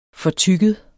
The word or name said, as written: fortygget